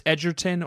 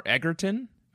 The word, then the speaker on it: Edgerton: On whether it's Edgerton or Egerton, but I think either's fine.